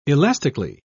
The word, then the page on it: elastically: British English